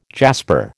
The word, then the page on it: jasper: American English